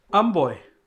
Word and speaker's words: Amboy: How do you pronounce the name of the book? How are you pronouncing it? – Amboy. – Amboy, okay. – Which is a mix between American boy.